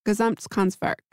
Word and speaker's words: Gesamtkunstwerk: So ceramics, fashion, furniture, architecture, and graphic design all converged into, I am going to attempt to say this properly, Gesamtkunstwerk. – Yes. Yes.